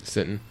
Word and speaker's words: Sitton: Sitton.